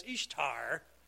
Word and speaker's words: Ishtar: Her name appears on the Assyrian monuments as Ishtar, pronounced the same way that we pronounce Easter.